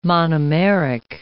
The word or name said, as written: monomeric